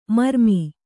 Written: ಮರುಮೈ

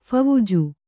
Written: ಫವುಜು

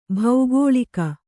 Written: ಬಾಕುಳಿಕ

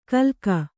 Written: ಗಿಲಕಿ

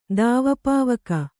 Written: ದಾವಪಾವಕ

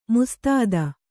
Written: ಮುಸ್ತೈದೆ

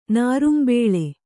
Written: ನಾರಿಂಬಳ್ಳಿ

